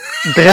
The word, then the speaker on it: Drenthe: is it Drenthe? – Drenthe. – How do you say that one? Oh, Drenthe. Okay.